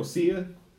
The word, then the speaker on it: Sia: It's kind of like the girl Sia.